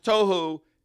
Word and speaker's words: tohu: T-O-H-O-O. It's pronounced tohu in Hebrew.